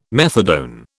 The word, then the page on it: methadone: British English